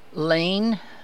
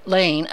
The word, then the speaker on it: Lahn: Lane, I think is how it's pronounced, L-A-H-N, upends Feastra in primary.